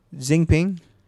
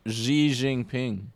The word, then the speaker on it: Xi Jinping: You know that that's not how you pronounce that. – Xi Jinping.